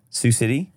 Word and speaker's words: Sioux City: No, it's there's a lot of vowels in an X. – S-I-O-U-S. – Yes. – Yes. Sioux. It's just, it kind of makes sense.